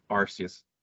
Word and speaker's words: Arceus: Yeah, but it's fine because in Japanese it's Arceus, and that's, you can't really hear it then. But it is pronounced that way. You can look at the katakana on the Japanese version to see how it's supposed to be pronounced. That's just a tangent. I'm sorry, but very important. Pronounce it Arceus.